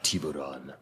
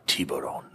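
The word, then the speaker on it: Tiburón: But I don't think Tiburon, Tiburon. You got to just say it like that, Tiburon. – Tiburon.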